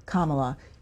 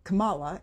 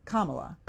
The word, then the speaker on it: Kamala: Congressman, the vice president pronounces her first name Kamala. You mispronounce it. Do you do that on purpose? – No, ma'am. I don't know how she pronounces it. I've never... This is the first time anybody's ever told me that. How did I say it? – You said Kamala, and it's Kamala.